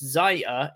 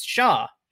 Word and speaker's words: Xia: It's not pronounced Xia, it's Sha. Sha Li. X's make an S sound, but through the teeth.